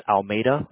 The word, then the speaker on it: Almeida: And then my last name is Almeida.